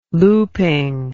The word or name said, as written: looping